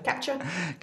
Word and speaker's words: CAPTCHA: CAPTCHA.